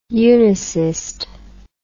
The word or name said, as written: unisist